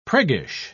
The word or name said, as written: priggish